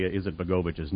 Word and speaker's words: Izetbegovic: By the way, I mispronounced Ali Izetbegovic's name. I left out a syllable. It's Izetbegovic, not Izetbegovic.